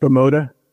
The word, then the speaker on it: Bimota: There was a tribute to, how do you say it? Bimota, B-I-M-O-T-A. – I think it's just Bimota.